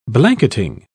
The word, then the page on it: blanketing: British English